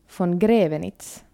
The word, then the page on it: von Graevenitz: finlandssvenska